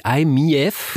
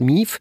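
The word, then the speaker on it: i-MiEV: Also das ist der Mitsubishi iMIEV, ja. – So müsste man eigentlich aussprechen. Aber es ist natürlich in Deutschland MIEV.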